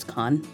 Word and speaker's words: Kahan: By Noah Kahan. – Is it Noah Kahan? – Maybe. – K-A-H-A-N? – Yeah, is it Kahan? – It's Kahan.